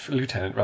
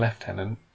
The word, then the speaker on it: lieutenant: I'm going to pick you up on having said lieutenant rather than lieutenant. – I'm oh, sorry. Did I say that wrong? – You said it the American way, not the English way.